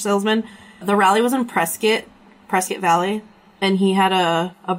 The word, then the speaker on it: Prescott: And they literally have shirts that say it's pronounced Prescott, like biscuit.